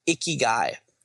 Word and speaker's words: ikigai: Instead, they have a word called ikigai, which is spelled I-K-I-G-A-I. Ikigai.